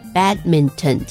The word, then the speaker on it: badminton: 好，来，我们先介绍这个字，它叫做 badminton、 badminton is spelled B-A-D-M-I-N-T-O-N。很多小朋友可能看到这个单字会直接把它分成三个音节、 badminton 这样子念、但是你会发现其实真正的、外国人他们在讲这个字的时候，后面他其实就是用一个嗯的音 badminton 这样发音的字典打，他还是会念 badminton 给你，因为他那个是机器的发音。